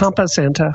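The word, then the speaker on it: Campo Santo: Since that's happened, Campo Santa, I can't remember how this is pronounced. – Yeah, but Campo Santa. – Is it Campo Santa? Oh, thank you.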